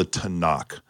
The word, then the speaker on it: Tanakh: Did I say that correctly? – Yes, the Tanakh, the Hebrew scripture.